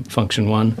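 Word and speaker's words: Funktion: Function One. – Yeah. – Thank you, Function One. – That's Function spelt with a K, isn't it? – As in funk.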